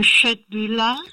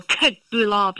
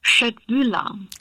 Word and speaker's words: Köttbullar: Zumindest hier in Deutschland kriegt man kein Gericht, wenn man nicht mit viel Gusto Köttbühler bestellt. – Ja. – Genau, weil in Schweden guckt man einen dann doppelt verwirrt an.